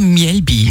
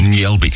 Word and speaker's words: Mjällby: Erster Gedanke natürlich, wenn man, oder wie spricht man es wirklich aus? – Ja, Mjällby. – Mjällby.